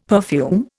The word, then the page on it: perfume: Indian